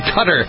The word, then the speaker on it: Qatar: spelled Q-A-T-A-R, pronounced Qatar.